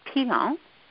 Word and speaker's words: Pilon: For an English pronunciation, that is very good. Actually, it's Pilon. – Pilon. – Mm-hmm. Yeah, okay. The N is more silent. It's like a oh.